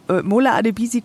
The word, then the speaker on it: Mola Adebisi: Äh, Mola Adebisi komplett richtig ausgesprochen.